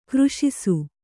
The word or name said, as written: ಗ್ರಾಸಿಸು